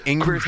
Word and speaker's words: Ingber: Because I got to say, like, pronouncing my last name correctly, you're like forever in my good book, because my whole life, you know, like the telemarketers call Mr. Englerger, Engler, for some reason, it's a pretty simple Ingber.